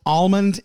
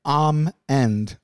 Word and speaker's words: almond: Both almond and almend are correct. – But both of those are L's.